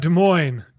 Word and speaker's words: Des Moines: Is it Des Moines? How do you say that? – Des Moines, Iowa. You were okay, close.